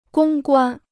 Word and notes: 公关: gōng guān

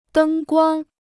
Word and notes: 灯光: dēng guāng

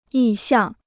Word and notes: 意向: yì xiàng

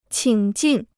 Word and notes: 请进: qǐng jìn

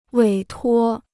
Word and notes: 委托: wěi tuō

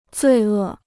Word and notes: 罪恶: zuì è

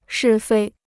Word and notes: 是非: shì fēi

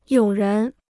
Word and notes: 有人: yǒu rén

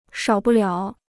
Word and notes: 少不了: shǎo bu liǎo

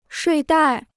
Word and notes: 睡袋: shuì dài

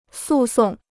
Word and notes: 诉讼: sù sòng

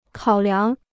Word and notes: 考量: kǎo liáng

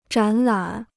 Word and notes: 展览: zhǎn lǎn

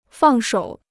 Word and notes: 放手: fàng shǒu

